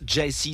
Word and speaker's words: Gyasi: Pronounce Jesse, c'est le nom de cet album. Jesse, ça s'écrit G-Y-A-S-I si tu veux faire des recherches.